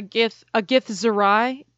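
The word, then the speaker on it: Githzerai: Okay, so for the listeners, a Gith Zerai, am I saying that right? – Yes.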